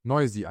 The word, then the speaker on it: Noisy: Noisy einfach, so wie es geschrieben wird quasi.